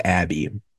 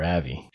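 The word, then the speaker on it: Avy: I guess it must be Avi because I thought it was Abby the whole time. – Yeah, it's spelled A-V-Y. I think they called her Avi.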